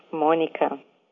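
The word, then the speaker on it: Mônica: The little accent that you see on top of the letter O is called a circumflex. And what that does is it stresses the sound of the vowel O. So if you speak Portuguese and you see my name, you know it's pronounced Monica.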